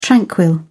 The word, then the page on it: tranquil: British English